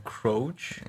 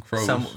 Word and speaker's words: Croce: I don't know how to say his name. – Croce? – Croce.